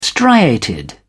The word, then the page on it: striated: British English